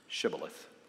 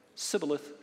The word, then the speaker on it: Shibboleth: And the Gileadites then had a password that they had to be able to pronounce, Shibboleth. And of course, the Ephraimites couldn't say it. What could they say? Sibboleth, Sibboleth. And so when they advanced to be recognized and they responded with the password, they knew that they were not truly of Gilead.